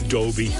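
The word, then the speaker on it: Dobey: I called him Chris Doby. It's Doby. – Oh, is it? – Yeah, as in surround sound.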